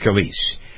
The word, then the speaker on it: Scalise: Is it Scalise or Scalise? I've forgotten how to pronounce that. It's Scalise. I remember what happened to him.